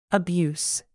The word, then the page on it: abuse: plural